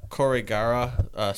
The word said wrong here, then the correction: Aokigahara: Aokigahara is how I would pronounce it.